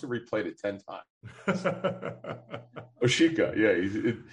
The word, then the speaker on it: Awosika: I've been saying Awosika for a long time, but it is not correct. It is Oshika.